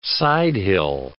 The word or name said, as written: sidehill